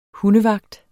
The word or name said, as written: hundevagt